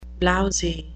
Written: blousy

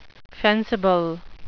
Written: fencible

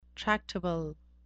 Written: tractable

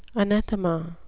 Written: anathema

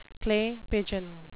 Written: clay pigeon

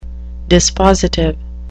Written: dispositive

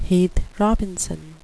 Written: heath robinson

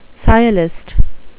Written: sciolist

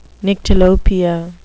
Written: nyctalopia

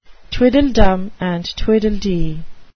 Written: tweedledum and tweedledee

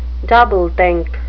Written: doublethink

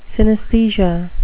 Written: synesthesia

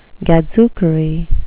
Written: gadzookery

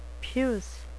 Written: puce

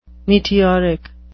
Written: meteoric

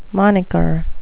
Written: moniker

